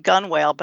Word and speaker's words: gunwale: I mean, one of them that I noted was the word gunwale, you know, is spelled G-U-N-W-A-L-E. So it's spelled gunwale, but it's pronounced gunwale. And then there's a word, it's spelled forecastle, F-O-R-C-A-S-T-L-E, and it's pronounced forecastle.